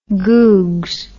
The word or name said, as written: googs